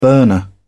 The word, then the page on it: burner: British English